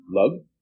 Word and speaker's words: Lug: It was named after the Celtic god Lug, or Lu, depending on how you want to pronounce it, but also came to be called Lyon, and he moved there.